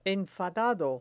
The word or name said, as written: enfadado